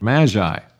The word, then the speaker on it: magi: Or in the Greek, magi is the proper word and pronunciation.